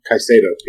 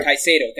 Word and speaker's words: Caicedo: Most recently, it's been Colombian striker Juan Fernando Caicedo, if I'm pronouncing that correctly. – Caicedo, yeah. – Caicedo, thank you.